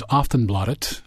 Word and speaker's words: Aftonbladet: You're a reporter with Sweden's Aftonbladet. I'm pronouncing that correctly? – You are pronouncing that correctly.